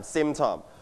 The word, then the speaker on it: symptom: Now, symptom. All right, can you listen to the audio twice? The first time. – Symptom. – One more time. – Symptom. – Your turn. Symptom. Symptom. นักเรียนปลายทางอย่าลืมออกเสียงตามคุณครูและเพื่อนๆต้นทางด้วยนะครับ Everybody, one more time. Symptom. You stress on the first syllable.